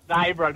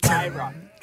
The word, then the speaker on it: Daybro: Can we just clarify the pronunciation of Daybro, please? – We have received the phone call. – Would you like to correct either Dobbo or myself on how to pronounce Daybro? – Leisel's spot on. It's Daybro, Dobbo. Daybro.